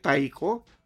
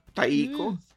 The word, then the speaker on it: taiko: Taiko? Taiko? – Yeah.